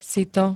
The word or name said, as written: sito